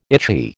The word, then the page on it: itchy: American English